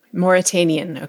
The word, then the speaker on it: Mauritanian: Mauritanian, okay.